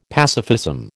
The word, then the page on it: pacifism: American English